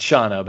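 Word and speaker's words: Shahna: Uh, for the part of uh, Shahana, it was actually it's Shana, but it's spelled S H A A S H A H N A, so it looks like Shahana or something.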